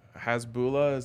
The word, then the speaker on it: Hasbulla: Um, did you see the video of Hasbulla, Is that how you pronounce yeah, that? Yeah.